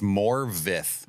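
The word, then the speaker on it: Morfydd: I always feel like I'm pronouncing her name wrong. – It's Morvith. – Morvith Clark. – It's not spelled that way at all. It tries to trick you.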